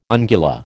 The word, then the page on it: ungula: American English